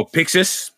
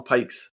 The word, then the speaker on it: Pixis: And then I always pronounce his name wrong. The guy's name that starts with a P, the other general, the Rose captain. – Oh, Pixis? – Yeah. – Yeah. – I was gonna call him Pikes.